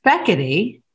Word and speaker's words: Fekete: Butchered. – Fekete? – Well, no, it's Fekete.